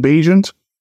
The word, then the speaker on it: Bagent: It probably will be Tyson Bagent. Rhymes with agent.